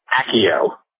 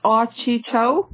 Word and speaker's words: Accio: In the movies, it's pronounced Accio. And also in the video games, I think it's pronounced Accio. Uh-huh. And in the audiobook, it's pronounced Axio, which is where I heard it first. Axio. Which is why my song was originally called Axio Deathly Hallows.